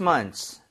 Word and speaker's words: months: 그 이게 그래서 여러분들 원래 정확하게 하면은 months가 실제로 발음이 되기는 해요. – Yeah. – months, months. 근데 이거를 천천히 하면 더 이상하긴 하죠, 그렇죠? 네. 그래서 빠르게 발음을 하면서 거의 사운드가 나오기 마련입니다. So months, months 이렇게 발음하셔도 괜찮아요.